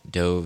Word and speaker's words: Deux: There's 9 Million and Doe Visages. How do you say that one? D-E-U-X. It's like faux.